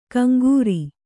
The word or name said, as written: ಕಂಕರಿ